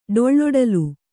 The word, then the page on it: ಡೊಳ್ಳೊಡಲು: kannada